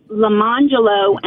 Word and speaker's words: Lamangelo: The names were spelled lemon jello and orange jello, but they're pronounced Lamangelo and Arangelo.